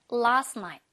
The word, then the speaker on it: last night: So, 아니, 얘들아, so가 아니라 그냥 saw 라고 발음해주시면 되고, last night 할 때는, t 발음은 그냥 날리세요. last night.